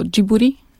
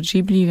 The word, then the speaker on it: Ghibli: Tak, Ghibli samo w sobie to jest słowo arabskie, a Japończycy wymawiają je w ogóle jako Jiburi. Taki zapis funkcjonuje, więc myślę, że no, można się też trzymać po prostu amerykańskiej wymowy Ghibli, więc możemy przy tym pozostać.